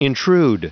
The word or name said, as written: intrude